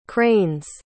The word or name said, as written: crane's